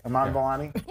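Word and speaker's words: Iman Vellani: Amon Vellani has shown... Am I saying your name right? I'm so bad. Eric says it right. Amon Vellani? – Yeah.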